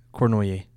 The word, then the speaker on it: Cornoyer: Cornoyer. – Okay, so you say it the French way.